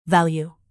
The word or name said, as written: value